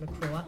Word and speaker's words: McGrath: September 19th, Patrick McGraw, spelled as McGrath, but it's pronounced McGraw, and Siobhan Carroll.